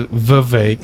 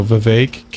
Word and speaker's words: Vivek: He says Vivek. – Vivek. – And it rhymes with cake. – Vaswami. – So Vivek, cake.